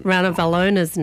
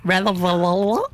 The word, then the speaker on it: Ranavalona: Now, I was saying it off air to myself, and then when the microphone was coming on, I'd be like, Ranavalola.